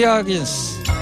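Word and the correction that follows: Higgins: 카사블랑카 하긴스가 아니고 히긴스입니다. 네, 가수 이름을 제가 버티 히긴스라고 읽어야 되는데 하긴스라고 읽어가지고 예.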